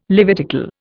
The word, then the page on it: Levitical: Indian